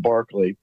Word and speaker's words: Berkeley: So it's spelled like Berkeley, but pronounced Barclay.